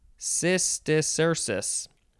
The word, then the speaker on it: Cysticercus: Cysticcercus. Cysticercus. All right, next is bladder wart. One word, wart is spelled W-O-R-T.